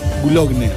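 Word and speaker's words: Boulogne: Es en Bologna. ¿Cómo se pronuncia esto? – ¿Bulogne? – Bulogne, ahí está.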